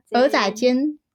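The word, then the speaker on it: 蚵仔煎: 对，然后国中的暑假在鹅阿珍店，鹅阿珍，鹅阿珍，鹅阿珍是怎么念？ – 煎，鹅啊，鹅仔煎店打工之后，高高中的时候比较少打工，但是就有兼职，就会去一些那个端端那个盘子的餐厅工作。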